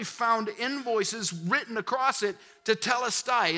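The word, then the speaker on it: tetelestai (τετέλεσται): It's called, it's pronounced tetelestai.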